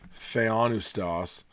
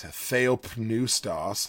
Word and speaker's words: Theopneustos: Well, so James White, who is a Reformed apologist and like Greek, just Greek scholar, he pronounces it "theonustos." Um, if you're from like Master Seminary, like John MacArthur's area, they would pronounce it Theopneustos, Theopneustos, because it's got the word pneuma in it, which has a P.